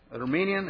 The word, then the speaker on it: Arminian: And that was the first time that I read this word, Arminian, is the right pronunciation? Arminian. And I said, when I see the characteristics, I said, whoa!